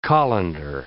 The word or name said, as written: colander